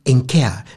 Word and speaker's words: Henker: Allerdings ist diese Assoziation lediglich der Klangnähe des Familiennamens des Ehepaar Henker geschuldet. Aha. Also ausgesprochen Enker. Ist ja ein französisches Ehepaar. Enker, also ohne H.